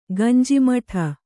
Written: ಗಂಜಿಮಠ

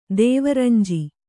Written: ದೇವರಂಜಿ

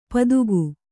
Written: ಪದುಗು

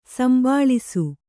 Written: ಸಂಭಾಳಿಸು

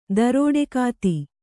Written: ದರೋಡೆಕಾತಿ

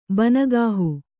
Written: ಬನಗಾಹು

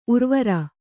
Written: ಊರ್ವರೆ

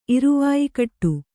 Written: ಇರುವಾಯಿಕಟ್ಟು

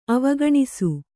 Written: ಅವಗಣಿಸು

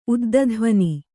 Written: ಉದ್ದಧ್ವನಿ

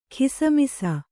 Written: ಗುಸುಮುಸಿ

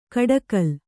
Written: ಕೋಡ್ಗಲ್ಲು

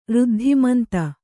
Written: ಋದ್ಧಿಮಂತ

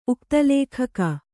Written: ಉಕ್ತಲೇಖಕ

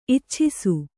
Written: ಇಚ್ಚೈಸು

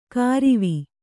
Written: ಕಾರಿವಿ

